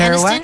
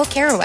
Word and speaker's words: Kerouac: How do I say his last name? Carax? – Jack-a-rax. – Jack-a-rax on the road. Please correct me if I'm wrong. Jennifer Is it Kerouac? – Aniston. Oh, Kerouac.